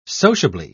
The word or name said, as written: sociably